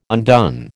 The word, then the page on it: undone: American English